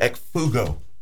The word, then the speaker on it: ekphugo: And the word escape, by the way, is from the Greek word ekphugo. Ekphugo is how you probably pronounce it best, with that kind of a Y sound after yeah.